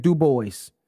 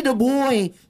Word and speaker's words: Du Bois: It's a Catholic church across the street from a school named Du Bois. Let that just. – Wow. – Let that. – Yeah. – That's fire. – That's woke. I'm. – Whoa. W-E-D Du Bois. – Hey! It's pronounced Dubois.